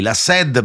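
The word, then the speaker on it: La Sad: Partiamo da eh, formazioni come il trio dei La Sed, perché si pronuncia così, Bunker 44, eh, Maninni, i Santi francesi, Clara.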